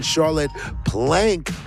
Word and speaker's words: Charlotte Plank: Before that, we had the sounds of Ogri's Debris with Misa Katze, preceded by Jiggets and Charlotte Plank with Car Crash. And earlier, I pronounced the name Charlotte Prank, so forgive me for my earlier mistake.